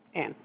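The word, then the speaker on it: Anne: Ann, okay. – Mm-hmm. – I know how to spell that, I think. Okay. – Yeah, with an E. – Oh, E-N-N, is that right? – No, ma'am.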